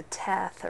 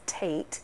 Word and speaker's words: Teth: The passage under the Teth, or it's actually pronounced Tate, letter of the Hebrew alphabet.